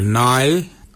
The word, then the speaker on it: ngarly: Wano is spelled W-O-N-D-I-L. Wano. Your nai is your underarm. Nai is spelled N-G-A-R-L-Y.